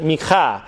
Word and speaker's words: Micah: The title is Micah, or in Hebrew you pronounce it Micha.